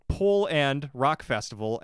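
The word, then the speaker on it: Pol'and'Rock Festival: It used to be called Woodstock Festival Poland, but it is now called Pol and Rock Festival. And if you're wondering why I pronounced it weird, it's P-O-L apostrophe A-N-D apostrophe Rock Festival.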